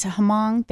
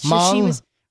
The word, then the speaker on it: Hmong: It's Hmong, the H-M-O-N-G. – I don't know. – Yes, Hmong? She was of that ethnicity.